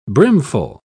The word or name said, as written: brimful